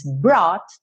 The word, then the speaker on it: brought: Brought, tem um GH mudo aí.